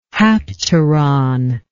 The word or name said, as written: hapteron